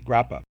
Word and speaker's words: grappa: You just told me that there was a particular permit for Gruppo, and we have no terminology in North Carolina for Gruppo. – It's Grappa, G-R-A-P-P-A, Grappa.